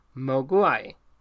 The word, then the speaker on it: Mogwai: In Cantonese, Mogwai means devil, demon, or gremlin. The Mandarin pronunciation is Mogwai.